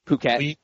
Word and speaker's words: Phuket: So there's a city in Thailand that's spelled P H U K E T. Phuket. You, you pronounce it Phuket, and I would call it the Pookit Browns.